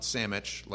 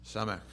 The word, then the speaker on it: Samek: Not Samich, like make me a sandwich, but Samek. Let's read the verses under this section.